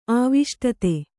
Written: ಆವೇಷ್ಟಿತ

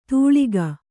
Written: ಧೂಳಿಕ

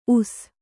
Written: ಊಸು

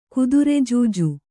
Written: ಕುದುರೆಜೂಜು

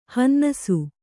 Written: ಹನ್ನಾಸಿ

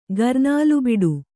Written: ಗರ್ನಾಲುಬಿಡು

